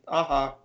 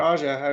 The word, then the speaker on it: Aja: Yeah, but it was Brubaker and Fraction with Aha yeah. or Aja, do you pronounce it. I have no idea. – Yeah, either either one. – I don't know if it's a soft J or hard J.